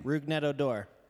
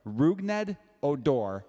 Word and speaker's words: Rougned Odor: Rougned Odor. – Rougned Odor.